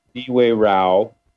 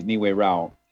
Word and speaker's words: Niue Rao: A lot of people have a hard time pronouncing it. Niue Rao. It's a Shipibo phrase, Niue Rao.